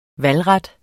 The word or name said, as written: valgret